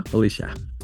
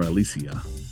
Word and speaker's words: Alicia: Alicia. Or Alicia. – Either way, I know people that pronounce that both ways.